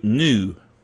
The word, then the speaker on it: GNU: Linuxy type folks like recursive naming, like GNU, new. There is an animal called a new, and it's pronounced the same as N-E-W, new, but GNU is news, not Unix.